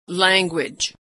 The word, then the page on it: language: /w/